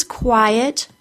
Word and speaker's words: quiet: That second syllable of quiet is a schwa, so it doesn't get much sound, but it is there. Since quiet and quite both have a long I,